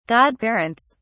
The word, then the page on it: godparents: British English